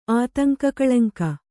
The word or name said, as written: ಆತಂಕಕಳಂಕ